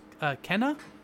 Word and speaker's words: Kena: Yeah, the next game that we saw was it's pronounced Kena, K-E-N-A, Bridge of the Spirits, which is from Ember Lab, which looks awesome.